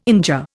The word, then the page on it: injure: Indian